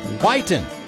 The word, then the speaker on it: Whiteen: And I do want to say, there has been some confusion about how to say the guy's last name. It's spelled W-H-I-T-E-E-N. Now, there were people in Iliopolis named Whiten, but his name is pronounced Whiten.